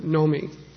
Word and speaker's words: Navami: Navami, it's written N-A-V-A-M-I, Navami. We pronounce it Nomi, Ram Nomi, which means the ninth day of the moon that Lord Ram had appeared on.